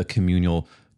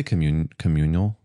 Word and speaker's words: communal: And what a community does is make people feel supported, seen, and have a place to like practice the communal, how do you say communal? – No, that's right. Communal. Yeah. – Communal. Practice the communal liquid U, fat A.